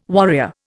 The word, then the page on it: warrior: Indian